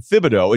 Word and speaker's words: Thibodeau: How about Thibodeau? – It's always been Thibodeau. It's just the Tibbs nickname screws everybody up. – But people called him Thibodeau for 10 years.